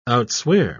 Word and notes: outswear: British English

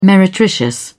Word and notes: meretricious: British English

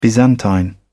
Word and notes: byzantine: British English

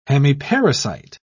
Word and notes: hemiparasite: British English

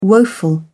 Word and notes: woeful: British English

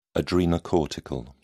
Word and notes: adrenocortical: British English

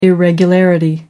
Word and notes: irregularity: American English

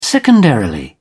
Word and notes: secondarily: British English

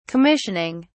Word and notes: commissioning: British English

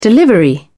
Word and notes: delivery: British English

